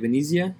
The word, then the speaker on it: Venezia: Venezia? – Venezia.